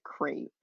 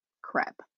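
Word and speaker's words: crêpe: Yeah, if you want to spell it the French way, you'll need to add a circumflex over the first E and pronounce it to rhyme with step, crepe.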